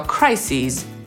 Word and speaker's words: crises: Kryzys to crisis, a kryzysy to crises. Zmienia się i wymowa, i pisownia jego końcówki.